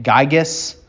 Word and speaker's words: Gyges: depending on who's pronouncing it, Gyges, G-Y-G-E-S, or this one's even better, Gugu, G-U-G-U.